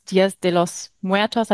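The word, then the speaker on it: Días de los Muertos: Ich glaube, wir alle kennen die Bilder aus Mexiko vom Tag des Todes, Dias de los Muertos.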